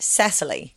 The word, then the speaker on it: Cecily: No, in Danish, apparently it's Cecilia. – Oh. – But in English, she pronounces it Cecily.